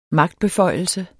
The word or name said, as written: magtbeføjelse